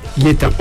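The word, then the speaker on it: Lieta (Лето): Lieta. – On va le prononcer. On va le dire Leto, parce que c'est L-E-T-O, mais en russe, ça se prononce Lieta.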